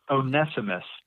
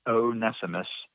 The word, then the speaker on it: Onesimus: And I'm going to go with Onesimus today as the proper pronunciation. Onesimus.